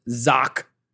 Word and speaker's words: xoc: Mayan word zoc, pronounced like shock, spelled zoc though, X-O-C.